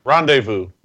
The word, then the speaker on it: rendezvous: Rendezvous. – That's it. – Hmm. – For bonus points, spell it. – R-E-N-D-E-Z-V-O-U-S.